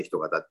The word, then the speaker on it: 人形: っていう字を、人形って書いて人型って読むんですけど、